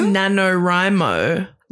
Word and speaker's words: NaNoWriMo: It's NaNoWriMo. Like oh. National Novel Writing Month. – Yeah. – Okay. I've got to be honest. Like, no offense to anyone who's NaNoWriMo,